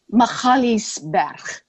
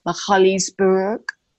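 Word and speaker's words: Magaliesberg: Machaliesberg. That is the Afrikaans pronunciation of a specific range of mountains. The anglicized version of that would be Machaliesberg. The African pronunciation would be somewhere in between Machaliesberg, Mahila.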